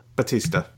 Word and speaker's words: Bautista: am I saying his name right? Is it Bautista or Bautista? – Bautista.